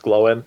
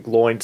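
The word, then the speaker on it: Glóin: The consensus is pronounced Glowen, but I didn't realize his father's name was pronounced Groen, but spelled Groin. So wow. – So by my pronunciation, he would have been Gloin, son of Groin.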